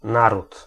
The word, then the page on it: pays: [ɛ̃ pe.ji]; France (Paris)